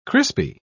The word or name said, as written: crispy